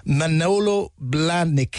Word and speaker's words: Manolo Blahnik: Security staff spotted the pink, and I'm going to say this slowly because I don't know if I'm pronouncing it correctly, Manolo Blahnik. Is that it? Yeah, okay. Yeah, and Claire's giving me the nod that that's some way close to it.